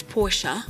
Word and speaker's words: Porsche: Now, the group does include brands such as Audi, and apparently it's not Porsche. Apparently it's Porsche. – Okay. – We've been saying it wrong. – It's not Porsche. Porsche. – It's Porsche. – It's the A.